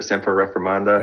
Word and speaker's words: Semper Reformanda: Semper Reformanda? – That's it, man.